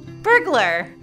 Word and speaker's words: burglar: And this concludes today's Look at Kid Criminals, where we learned the only real crime are people who pronounce burglar with three syllables. – Burglar, burglar, burglar.